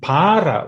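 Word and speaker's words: pāra: And para with a long A is a what they call a tadita or secondary derivation, which means the place that is other.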